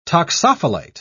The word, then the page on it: toxophilite: British English